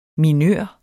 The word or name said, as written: minør